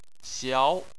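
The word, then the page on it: xiao: tone 2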